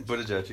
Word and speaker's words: Buttigieg: Buttigieg, how do you say it? Buttigieg. – Buttigieg, you got it.